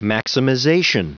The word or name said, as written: maximization